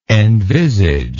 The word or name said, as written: envisagement